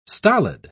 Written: stolid

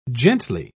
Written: gently

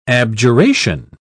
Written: abjuration

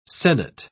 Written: sennit

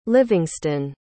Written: livingston

